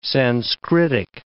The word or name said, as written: Sanskritic